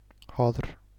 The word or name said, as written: Höðr